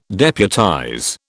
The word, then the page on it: deputize: British English